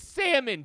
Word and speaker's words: salmon: My man was trying to have a Jessica Simpson, is this chicken or is this fish moment by trying to pronounce salmon wrong. It's salmon, Jonathan Abram. You know it's salmon. Everyone knows it's salmon. No one's ever called it salmon, but you just wanted to get attention because that's what your whole shtick is.